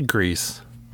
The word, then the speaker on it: Gris: Is it Grease? How do you say that there? – I say Grease. – Grease? All right, yeah. G-R-I-S.